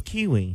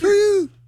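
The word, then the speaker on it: KUIU: I thought it was Kiwi. – Kuyu. – How you say that? – Y'all, companies make it? Kuyu.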